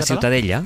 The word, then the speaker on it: Ciutadella: ¿cómo se pronuncia? – La ciutadella.